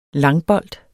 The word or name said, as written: langbold